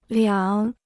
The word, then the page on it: 两: liǎng